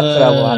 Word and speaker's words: 지구라트: 지그라트의 유산이었나 그랬을까. 한번 해볼 만한 게임이고. 아, 마지막으로는 리사라는 게임인데. 그 미사? 리사, 리사. 리사, 어. LISA. 그러네요. – 게임은... 지구라트라고 하는 게 맞네.